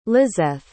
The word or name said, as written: lizeth